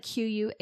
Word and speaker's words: Quay: I think I'm saying that right. It's like Quay.